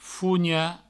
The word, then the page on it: fonhar: fougna; Provençal